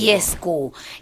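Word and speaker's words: Dieskau: No sé si se dice así, Fischer Diesco. – Seguro que la Diesco. Primera está bien pronunciada, la segunda te la digo. – Diesco.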